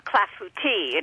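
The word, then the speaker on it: clafoutis: So I was looking at the Tuberi Clafouti. How do you pronounce that word? – That is clafouti in French.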